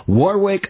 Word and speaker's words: Warwick: And this is my generic, dull, boring American English pronunciation. Warwick Marsh, W-A-R-Wick, W-I-C-K. And he has, you know, the super cool Aussie pronunciation of his own name.